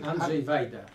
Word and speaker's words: Andrzej Wajda: Andrzej Wajda.